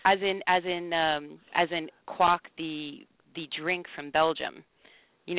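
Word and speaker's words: Kwak: So it's Quack yeah.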